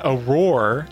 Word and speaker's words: Aurore: And then Aurore is actually how you pronounce it. It's the French word for dawn.